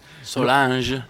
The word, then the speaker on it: Solange: Qual è la pronuncia esatta, visto che... – Solange. – Solange. Quindi, ecco, sì. Siccome viene scritto sempre in maniera le più disparate questo Solange, non sai mai se leggerlo alla tedesca, alla francese, oppure addirittura, in questo caso, alla cimbra.